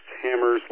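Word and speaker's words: HAMRS: It's something that I have used in the past that there are examples of on the web, the Simplex webpage, is Hammers, H-A-M-R-S, I think it's pronounced Hammers, like the, you know, tool that you drive nails with, but it's missing a vowel.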